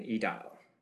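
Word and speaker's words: Aedile: All right, Edil. That's the pronunciation now.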